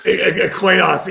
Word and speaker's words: Coinos: Dude, I love this because, so if you go to by the way, you guys pronounce it Coinos? It's CoinOS, right? Is it Coin Operating System? Coinos, yeah, we get asked that all the time. It's Coinos, yeah, but say whatever you want.